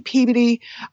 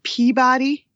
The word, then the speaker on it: Peabody: And when I say Peabody, everyone else pronounces it Peabody, but that's how we know you're a tourist.